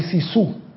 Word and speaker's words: sisu: En Finlandia se practica el sisu, palabra que no tiene forma de traducción, nada más es en finlandés, el sisu.